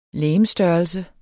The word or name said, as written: legemsstørrelse